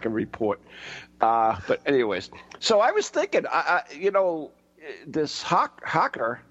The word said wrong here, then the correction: haka: I'm saying that wrong, right? – The haku? Haka. Haka. Haka. – Haka? Haka? – No, not said in Japanese. Haka.